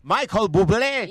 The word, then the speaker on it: Michael Bublé: El Michel Bubul. ¿Cómo era Bubul o qué? – Bubul. – Mejor que te hagan un bubulú, mi querida mía, para que pronuncies bien el Michael Bublé.